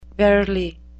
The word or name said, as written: verily